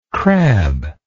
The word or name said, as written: crablike